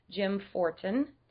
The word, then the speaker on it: Jim Fortin: So today, I have a very special guest, Jim Fortin.